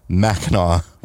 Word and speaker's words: Mackinac: We also, apparently on last week's podcast, we pronounced Mackinac wrong. We pronounced it Mackinac just because we were reading. We know it's Mackinac because we're from not far from there.